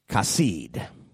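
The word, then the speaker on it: kasid (חסיד): In the Hebrew, it is C-H-A-C-I-Y-D, pronounced kasid. And it comes from the Hebrew term for grace, which is kesed.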